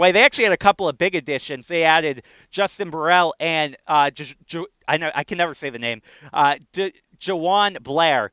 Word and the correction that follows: DeJuan Blair: DeJuan Blair.